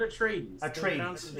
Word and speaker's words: Atreides: Atreides. I always have a problem pronouncing it. I think they, in the movie at least, I think they pronounce it Atreides.